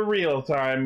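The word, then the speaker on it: REAL×TIME: and it's about time for the real time movie, the 01 movie. – Real X time movie. – Yeah. Well, you don't pronounce the X, it's like Hunter Hunter.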